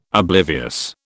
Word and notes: oblivious: British English

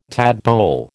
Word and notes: tadpole: American English

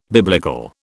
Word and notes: biblical: British English

